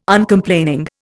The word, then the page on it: Uncomplaining: Indian